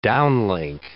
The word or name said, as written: downlink